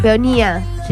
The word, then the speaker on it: peonía: Es una flor azul, es una peonia pintada en acuarelas. – Una peonia. – ¿Una qué? – Una peonia. Peonía. Ahí está, lo dije bien.